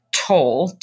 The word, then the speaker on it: Toll: It's K-A-T-H-I, Toll, T-O-L-L, or my email is Catherine.Toll@the Parker Avery Group.